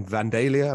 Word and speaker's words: Vandalia: Which is because it used to be in Vandalia. Am I saying that right? – That sounds right.